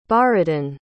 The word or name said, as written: borodin